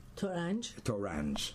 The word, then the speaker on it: Torange: Torange.